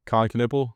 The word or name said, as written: Kon Knueppel